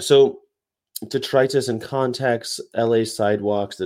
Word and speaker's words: detritus: If you use detritus in speech, remember to stress the second syllable as you do with the word arthritis and bronchitis.